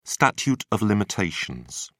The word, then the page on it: statute of limitations: British English